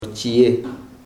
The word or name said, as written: chi.je